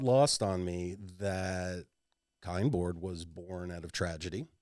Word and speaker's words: KyndBoard: KyndBoard. It rhymes with a particular after work mood enhancer that some of us like to partake in. Kind bud.